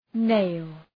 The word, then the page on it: nail: neıl